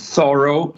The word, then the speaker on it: thorough: Per esempio, nella parola rough e tough si pronuncia come una F, nella parola tipo thorough invece c'è un GH finale che non viene pronunciato.